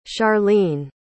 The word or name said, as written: charleen